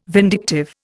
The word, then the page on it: vindictive: Indian